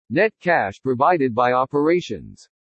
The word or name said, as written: NET CASH PROVIDED BY OPERATIONS